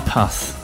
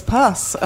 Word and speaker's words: Puth: P-U-T-H. – Okay, thanks. – So I'm sure that's how you pronounce it. – Puth. I thought it was pus.